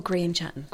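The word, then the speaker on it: Grian Chatten: Oh, Grian Chatton.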